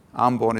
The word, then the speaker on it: Ambon: Ambon is how we pronounce it in Indonesia.